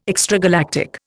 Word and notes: extragalactic: Indian

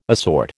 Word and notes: assort: American English

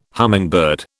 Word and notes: hummingbird: British English

